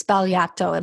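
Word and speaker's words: Sbagliato: And just to let you know, the G is silent. It's Spagliato. It makes a wild sound.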